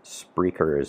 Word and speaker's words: Spreaker: Spreaker is spelled like speaker, but with an R, S-P-R-E-A-K-E-R.